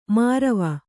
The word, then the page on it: ಮಾರವ: mārava